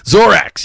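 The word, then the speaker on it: Xorax: It's spelled X-O-R-A-X. I think it's pronounced Zorax. I think it's pronounced Zorax, which that's how it's spelled. Not Lorax, because that would not be fucking true. Zorax, yes, Zorax.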